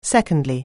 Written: secondly

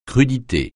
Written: crudité

